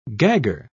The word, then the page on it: gagger: British English